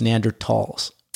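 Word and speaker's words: Neanderthal: Apparently, it's Neanderthals. It's a silent H.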